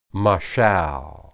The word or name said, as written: mashal